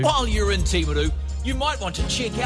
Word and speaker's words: Timaru: Lovely pronunciation too. Timaru.